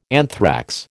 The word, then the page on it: anthrax: American English